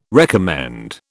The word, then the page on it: recommend: British English